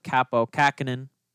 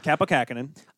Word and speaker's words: Kaapo Kähkönen: Cam Talbot being the new starting goaltender for them with Kaapo Kähkönen. – Yep, you said that right. Kaapo Kähkönen.